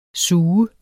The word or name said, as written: sug